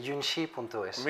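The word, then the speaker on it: Yunxi.es: Vamos a recibir a nuestro siguiente invitado, que es el CEO y cofundador de Yunshi.es. A ver, ¿me lo dices bien? ¿Cómo se debe pronunciar? Porque eso si quiero hablar en chino no me sale. Lo, has pronunciado perfectamente. Yunshi.es.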